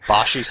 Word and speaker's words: Boschi: When you had Andre Sorrentino in there, along with, I guess it's Roland Boschi. I don't want to butcher his name. – Boschi sounds right.